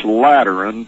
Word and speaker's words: Lateran: By terms of this Lateran Treaty, did I pronounce that right? – You did absolutely correctly so far.